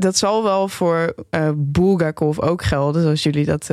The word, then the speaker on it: Bulgakov: Namelijk De Meester en Margarita van Michail Bulgakov.